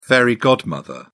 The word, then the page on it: fairy godmother: British English